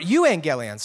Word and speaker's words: euangelion: Originally, the word gospel or good news in the Greek was this word angelion, okay? Or angelion. You could say it kind of some different ways, right? Angelion or angelion. Angelion or euangelion, sorry, I'm saying it wrong. Euangelion, actually means good news.